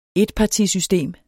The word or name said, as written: etpartisystem